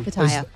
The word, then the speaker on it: Pattaya: That Pattaya or Pattaya or however you pronounce it. – Mm. Pattaya, yep.